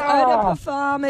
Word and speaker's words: eau de parfum: Oh, no, it's eau de parfum.